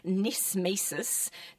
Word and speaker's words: knismesis: The first one is called nismesis, note the silent K, so it's actually K-N-I-S-M-E-S-I-S.